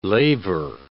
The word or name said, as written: laver